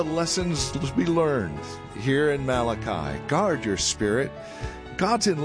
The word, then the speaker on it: Malachi: It just depends on how you get the syllable on the emphasis, right? It's Malachi, and that's where we're at today as we close out the week.